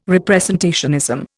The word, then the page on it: representationism: Indian